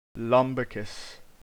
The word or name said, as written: Lumbricus